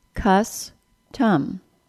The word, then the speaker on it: custom: In the word custom, C-U-S-T-O-M, the vowel sound in the first and second syllable is the same. In both cases, it's uh, cus-tum. However, there is a difference, and the difference is syllable stress.